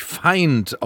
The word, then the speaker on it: FIND: Und dann fällt mir Holzkopf aber nicht ein, dass das Festival, das da gerade an der Schaubühne vor sich hin tobt, dass das nicht Find, sondern natürlich Feind ausgesprochen wird, weil es ja ein internationales Ding ist, international als Theater zu sehen ist.